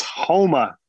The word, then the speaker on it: Houma: It's Houma, Louisiana, like home and then throw an A on there.